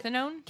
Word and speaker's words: cathinone: I'm not going to name the name, but it is a type of bath salt and a synthetic cathinone. Is that how you pronounce it? Cathinone? – Cathinone, yeah.